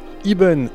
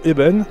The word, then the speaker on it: Iben: Iben ou Eben, car le E se prononce I en breton, c'est le nouveau projet parrainé, soutenu et mis en place par le Festival Interceltique de l'Orient.